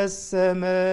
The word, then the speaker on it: السماء: سماء دي مد متصل، لأن الهمزة اتصلت بحرف المد في نفس الكلمة. كلمة السماء فيها المد بالألف وفيها الهمزة بعديه في كلمة واحدة، فبنمدها أربع حركات، تعد أربعة كده وانت بتنطق.